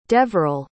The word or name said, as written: deveral